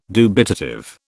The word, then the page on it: dubitative: British English